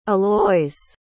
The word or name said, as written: aloys